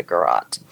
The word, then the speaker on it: garrote: This one looks like he was strangled to death with a garrote. That's how you say it.